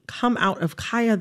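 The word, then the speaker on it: Khia: I am going to, in this conversation, pronounce Kaya, the rapper, with the long I. Kaya. okay? My neck, my back, mm-hmm. for those who need a refresher. Kaya, okay? Mm-hmm. The Kia Asylum is a mispronunciation of her name.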